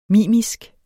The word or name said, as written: mimisk